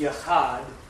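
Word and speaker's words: Yahad (יחד): Now, the Hebrew word for community, which is pronounced Yahad,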